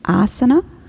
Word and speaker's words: Asana: Asana ist das erste A lang und das ist dann die Körperposition auch als Achtsamkeit oder Sitzhaltung bezeichnet. Und sonst gibt es aber auch Asana ohne langes A im Indischen und das wäre dann ein Pfeil, eine Rakete und hat eine ganz andere Bedeutung.